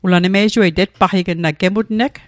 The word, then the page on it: gemutneg: Mi'gmaq